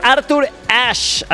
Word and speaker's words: Arthur Ashe: Oye, nos está escuchando nuestro querido amigo Ricardo Cázares, que me manda mensajito y nos dice: Pollito, es Arthur Ashe, así se pronuncia, y es el estadio de Estados Unidos en New York, se llama así.